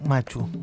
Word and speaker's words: Machu: Machu, Machu. – Machu, ¿vio? Machu. Por eso, por Machu Picchu, le erré.